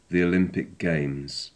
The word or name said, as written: the olympic games